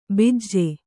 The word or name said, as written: ಬಿಜ್ಜೆ